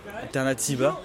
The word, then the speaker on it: Alternatiba: Donc, c'est Alternitaba. Alternatiba. Attends, je le prononce mal.